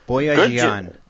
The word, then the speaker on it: Boyajian: Dr. Tabitha Boyajian? – Boyajian.